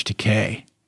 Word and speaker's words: Takei: That would be Alan Cumming, but George Takei is how you say it.